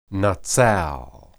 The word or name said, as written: natsal